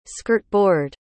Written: skirtboard

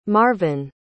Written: marven

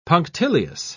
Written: punctilious

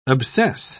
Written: obsess

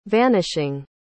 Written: vanishing

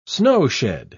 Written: snowshed

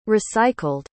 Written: recycled

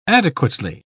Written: adequately

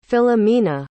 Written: filomena

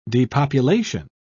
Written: depopulation

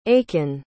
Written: aiken

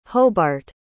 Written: hobart

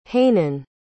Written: hanan

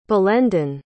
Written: balendin

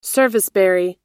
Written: serviceberry

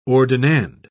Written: ordinand

